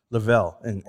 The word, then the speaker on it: Laval: Is it Laval or Lavelle? – Lavelle.